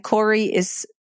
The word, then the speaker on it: Cori: And Cori is, it's very Canadian. It's spelt like sorry, just take off the S and stick on a C.